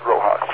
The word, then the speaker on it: RoHS: Okay, Rojas is written down as R-O-H-S. People pronounce it Rojas.